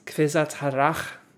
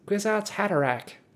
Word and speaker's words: Kwisatz Haderach: You know, when uh, Herbert wrote this, I mean, it, it looks German to me in German, I would pronounce this uh, quizatz Kvisatz Harach. Kvisatz Harach, yeah. But in English, I would say "quizatz, quizatz, quizatz Hattarak. Um, anyway.